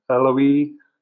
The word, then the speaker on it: Eloi: I used to pronounce it Eloi, Eloi, Lama Sabachthani. Yeah. The second half, I'm in total agreement with you. It's the Eloi, Eloi. I'm Southern. Eloi. You got to put a diphthong in there.